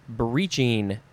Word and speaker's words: breeching: The first word is breeching. B-R-E-E-C-H-I-N-G. And this is a... could also be breeching. I guess it depends on where you are, if you want to do the long E or the I sound.